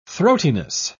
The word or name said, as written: throatiness